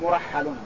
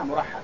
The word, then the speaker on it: مرحل: قالت مرحل، قولها مرحل كما تسمعون، هذا نطقه مرحل بضم الميم وراء مهملة مفتوحة وحاء مهملة مشددة، هكذا مرحل كمعظم.